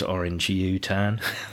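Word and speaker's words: orangutan: But it's actually pronounced orange U-tan.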